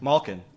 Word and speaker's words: Malkin: Malkin.